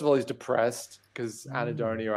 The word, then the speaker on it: Anhedonia: You have this one here, Anhedonia.